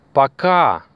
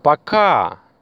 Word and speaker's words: пока: And the last word is пока. Пока. You see the stress? Where is it? Пока. Right. It's on the second syllable.